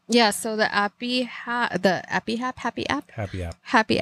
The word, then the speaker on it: HAPI: It's spelled H-A-P-I. – Happy. – Happy.